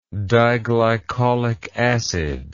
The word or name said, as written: diglycolic acid